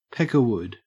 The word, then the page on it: peckerwood: Australian English